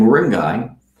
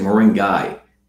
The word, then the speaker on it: moringai: So when it comes to immune health and moringae, by the way, some people say moringa or moringa, but in Tamil you say moringai, you know, like guy, but that's how you actually pronounce moringai.